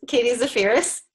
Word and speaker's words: Zaferes: And also, you got pretty close. Katie Zaferes.